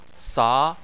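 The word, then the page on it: sa: tone 2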